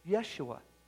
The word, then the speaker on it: Yeshua: Well, if you're going to say Jesus' name correctly, pronounce it, it's Yeshua.